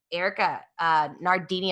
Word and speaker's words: Nardini: You guys have a female CEO, Erica Nardini. Am I saying that right? Okay, good.